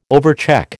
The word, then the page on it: overcheck: American English